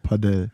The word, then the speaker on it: Padel: Heißt das Paddle oder Padel eigentlich? – Ich glaube, wir sagen, deutsche Leute sagen Paddle und Leute, die es wirklich richtig aussprechen, sagen Padel.